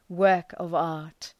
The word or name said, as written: work-of-art